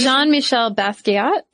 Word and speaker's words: Jean-Michel Basquiat: Jean-Michel Basquiat. – Yeah. – Did I say that right? I went to the pronunciation. – Jean-Michel Basquiat. – Yeah.